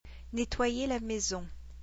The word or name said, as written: nettoyer la maison